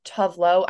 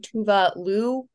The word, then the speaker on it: Tuvalu: Also, I should mention, I think the Swedish pronunciation of Tuvlo, I know that's like the American, I said that because I think most Americans pronounce it that way, but I think it's actually Tuvalu, which I, anyway, I thought I should add that. I didn't want to say that and for people not to recognize it, but that is the correct pronunciation.